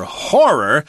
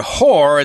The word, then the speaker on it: horror: If you just say one syllable there, whore, it sounds like a kind of a bad word for a prostitute. – Yep. – So you don't want to do that.